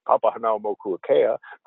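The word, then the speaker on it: Papahānaumokuākea: Papahanaumokuakea. In fact, a taxi driver in Honolulu gave me very positive feedback on my pronunciation of that.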